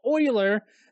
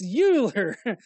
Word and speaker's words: Euler: It's not Euler. It's Euler.